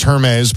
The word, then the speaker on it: Hermes: It's an original Hermes Birkin. Auctioned at Sotheby's Paris back in July of last year. – Pretty sure that's pronounced Hermes, but go on.